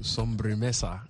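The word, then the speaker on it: Sobremesa: How did you come up with the name Somba Misa? – Okay, so Sombremesa. – Sombremesa, oh, thank you very much. That is a Spanish pronunciation? – Yeah, it is.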